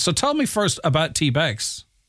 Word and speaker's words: TBEX: It is the TBEX. I presume there's no other way to pronounce that. Is there Rick? Rick Calvert, CEO of TBEX? – You got it right.